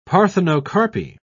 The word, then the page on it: parthenocarpy: British English